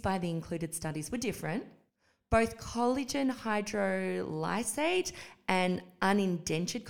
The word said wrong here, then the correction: undenatured: collagen, I might need undentured? Could you undenatured? Undenatured? – Yes.